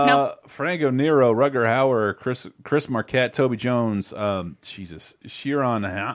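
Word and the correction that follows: Ciarán: Hines. Am I saying that right? – Kieran.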